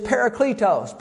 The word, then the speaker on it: Parakletos: Now, actually, the Greek word here is parakletos. Parakletos, I'll spell it. P-A-R-A-K-L-E-T-O-S. K-E-L-T-O-S, Parakletos, Parakletos, however you want to say it.